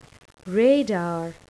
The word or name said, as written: radar